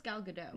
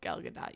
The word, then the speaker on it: Gal Gadot: I thought it was Gal Gadot. – Nope, Gal Gadot. You pronounce the T.